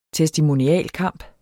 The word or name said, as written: testimonialkamp